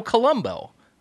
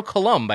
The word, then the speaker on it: Coulombe: is a Trader Joe, Joe Colombo uh, who uh, Joe Coulombe, I believe is how you pronounce his name, who started the company.